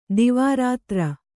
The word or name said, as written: ದಿವಾರಾತ್ರಿ